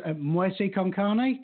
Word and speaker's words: Muerte con Carne: And it's Muerte con Carne. Did I pronounce that correctly? – Yeah, that's pretty good.